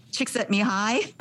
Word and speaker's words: Csikszentmihalyi: Flow was identified and defined by a psychologist with a very difficult name, Csikszentmihalyi.